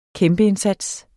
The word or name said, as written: kæmpeindsats